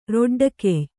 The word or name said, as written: ರೊಡ್ಡಗೈಯ